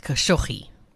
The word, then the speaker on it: Khashoggi: Yeah, it says on YouTube it's Khashoggi.